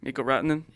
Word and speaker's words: Rantanen: 5-0-1 start to lead the Central Division. Nathan MacKinnon and Mikko Rantanen. Did I say that correctly? – Mikko Rantanen? – Yeah. 10 points for Rantanen and 9 points for MacKinnon, their goaltender, Grubauer. 4-0-1 with a .922 save percentage and a .257 goals against.